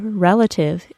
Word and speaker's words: relative: The first syllable of the word relative is stressed, and it is pronounced as re, with a short E sound, re.